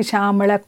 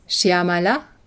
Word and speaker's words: Shyamala: Swami sagte uns, dass wir Shyamala falsch aussprachen, so wie wir es in Tamil gewohnt waren. Richtig ist Shyamala Komala.